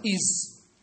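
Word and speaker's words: is: هذوك تغيرت في الحروف، بعض الحروف تكتب ولا تنطق إيز هي إي إس مش إي زد.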